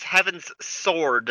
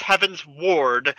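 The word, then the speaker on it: Heavensward: Yeah, it's like him, if you haven't noticed, he says Heaven's Sword. – To be fair, that's what I thought it was, too. – Yeah, it's, no, it's Heaven. It's Heaven's Ward.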